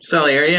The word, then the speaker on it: Salieri: Salieri, yeah.